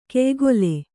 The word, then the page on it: ಕೆಯ್ಕೋಲು: keykōlu